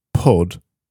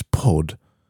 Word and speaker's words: pud: She said, if it's pronounced purd, I presume that's what she means, P-U-H-D. If it's pronounced purd, it means penis. But if it's pronounced pud, when it rhymes with good, which is pud, it refers to female genitalia.